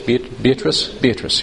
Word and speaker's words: Beatrice: And of course, you know, Dante's love for Beatrice, Beatrice, is that how you say it in English? Beatrice, yes.